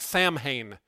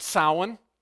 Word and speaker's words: Samhain: It looks like Samhain. You pronounce it Samhain,